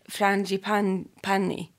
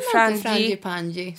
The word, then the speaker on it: frangipani: Jag, jag älskar ju till exempel då från LMIS har ju då frangipani. Mm. Jag, jag har ju fått själv för hur jag uttalar det här Aha. på vår Insta. Men jag säger, säger frangipanni. frangipani? – Frangipanni. Uh. Monoi.